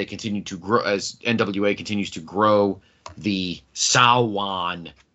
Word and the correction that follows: Samhain: Sawan. Sawin. – Sawin. Say it right. Sawin.